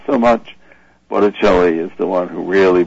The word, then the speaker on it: Botticelli: I like how you were talking about the Botticelli, I think I'm saying that right. – Botticelli, yeah.